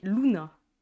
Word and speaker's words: Luna: Avant de débuter mon histoire, je vais préciser que mon prénom est Luna. L-U-N-A. Voilà, ça aurait pu s'écrire L-O-U-N-A ou L-O-O-N-A, mais ça s'écrit L-U-N-A.